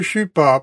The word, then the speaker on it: chupa: L'un est appelé le kiddushin, l'autre est appelé l'upa, ou le chupa pour bien prononcer le H.